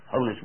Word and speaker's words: Honus: Honus Wagner or Honus Wagner? What is it? Honus? Honus Wagner.